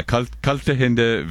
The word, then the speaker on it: kalte Hände: Kalte Hindes. – Yeah, kalte Hände, wärmes Herz.